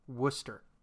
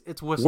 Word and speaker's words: Worcester: a particular town in Massachusetts that's spelled Worcester, and they pronounce it Worcester. – Worcestershire. – It's Worcestershire. – No, it's Worcester. – Worcestershire. – There's no shire in it.